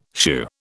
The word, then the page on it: shoo: British English